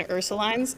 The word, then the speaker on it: Ursulines: And they were living in a 1,000 square foot second floor apartment at 715 Ursulines Avenue. And I hate the way New Orleanians pronounce it. It's spelled Ursuline with an S at the end, but they pronounce it Ursuline.